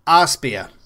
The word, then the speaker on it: Arespear: So it's spelled A-R-E-S-P-E-A-R. Arspear.